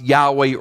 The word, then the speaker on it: Yahweh: That we sometimes pronounce Yahweh or Jehovah because Hebrew doesn't give us the vowel sounds and we're guessing a little bit.